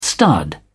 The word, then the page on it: stud: British English